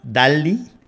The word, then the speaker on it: DALL-E: Ese link lo puse yo y se trata de una aplicación que se llama DALI. Get it, get it. DAL, D-A-L-L-E, pero en inglés se pronuncia dal como Wally.